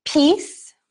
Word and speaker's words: peace: So, peace, we have a long E that is created by the EA sound. This E is silent. We do not pronounce it. Just like in the word name, we also don't pronounce that E.